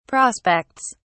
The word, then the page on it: prospects: British English